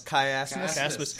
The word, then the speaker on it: chiasmus: C H I A S. chiasma, Chiasmus. Chiasmus. Chiasmus.